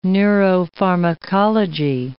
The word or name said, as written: neuropharmacology